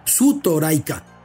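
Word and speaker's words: Sutoraika (ストライカー): Es decir, gol termina en L o R, como ellos lo pronuncian, es Goru. De esa manera, striker, como tiene ahí consonantes pegadas y en japonés eso no se hace, Sutoraika.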